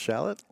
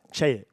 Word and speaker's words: Chaiet: You know, you're a partner at Brown, Lewis, Frankel, and is it pronounced Shallot? Chet, Chet, Very good.